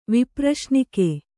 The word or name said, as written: ವಿಪ್ರಶ್ನಿಕೆ